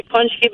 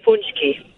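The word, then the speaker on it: pączki: How do you pronounce it? – It could be punchki, but we also say punchki.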